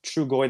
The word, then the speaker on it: Trugoy: Uh, De La Soul, one of their members, died Trugoy the Dove, I believe that's how it's pronounced, died in February.